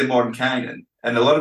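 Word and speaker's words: Mordenkainen: So it is Mordekainen officially in the pronunciation guides you find in 5th edition.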